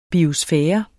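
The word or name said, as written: biosfære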